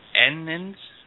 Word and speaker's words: Ennis: And that's Frank Humphreys and David, and I'm going to get his last name wrong, is it Ennis? – Ennis, E-double-N-I-S.